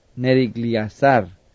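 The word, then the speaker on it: Neriglissar: Pero allí reinó él por 2 años y luego fue asesinado por su cuñado que se llamaba en español no sé cómo se pronuncia, pero por el inglés es Nerigliazar.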